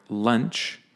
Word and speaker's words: lunch: And the word launch is pronounced like the word lawn or the word on. Or as you know, we have another host here, Sean, right? It's that sort of on sound. Whereas the next word, lunch, right, is pronounced more like punch, right? Lunch or under is that sort of sound. Lunch, punch, launch, lawn. So just watch the difference between those.